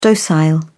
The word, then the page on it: docile: British English